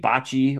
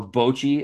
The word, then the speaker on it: bocce: They put lawn bowling, a.k.a. bocce or bochi, as some people pronounce it.